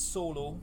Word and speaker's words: Szolo: So it's pronounced solo. – Solo. – Solo parlando. So but it's spelled S-Z-O-L-O.